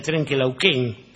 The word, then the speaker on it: Trenquelauquén: Tengo aquí un saludo para Angélica del Trenquelauquén, que es como se pronuncia, sí, que nos escucha.